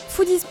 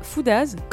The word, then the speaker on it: Foodies: Et puis Foodies Paris et non pas Foodaz, comme j'ai le malheur de le prononcer lors des présentations comme vous pourrez l'entendre dans un instant.